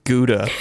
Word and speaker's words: Gouda: All of you out there, when she says how to, she means Gouda, the way normal people pronounce that cheese.